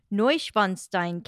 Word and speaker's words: Neuschwanstein: King Ludwig II was inspired by the Painted Palace design, which he used as inspiration in the construction of the famous Neuschwanstein Castle in Germany.